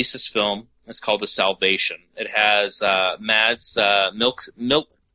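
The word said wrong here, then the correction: Mads Mikkelsen: Oh, Mad Mickelson.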